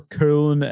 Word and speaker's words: Köln: I think it was originally a beer developed in Cologne, or Köln as it's properly pronounced, Germany.